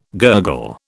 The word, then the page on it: gurgle: British English